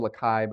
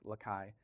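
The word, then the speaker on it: Les Cayes: it's called Lakai, but it's spelled Les Caius or whatever, but it's French, so it's Lakai.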